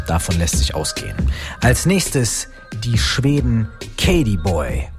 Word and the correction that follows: Kate Boy: oder beziehungsweise, nein, Entschuldigung, das habe ich ja auch falsch gesagt, Kate Boy.